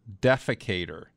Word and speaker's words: defecator: The male figurine named is translated to the defector. And no, actually, I didn't say that right. The male figurine's name is translated into the defecator, which makes sense since the porcelain doll is squatting with his pants down.